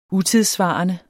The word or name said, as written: utidssvarende